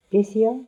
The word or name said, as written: Pythia